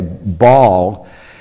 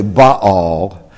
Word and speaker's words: Baal: I think the way you did it, Joan, is probably the best way, Baal. In Hebrew, there's a separation between the A, so it's actually Baal.